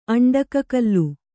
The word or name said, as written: ಅಂಡಕಕಲ್ಲು